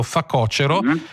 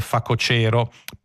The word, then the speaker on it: facocero: Secondo la Crusca... propongono come corretta la pronuncia con l'accento sulla penultima, accettando facocero, ma dicendo che quella più giusta dovrebbe essere facocero.